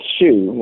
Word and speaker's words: Zhu: Is it Zhu or Zhu? – Oh, it's Zhu, like a shoebox.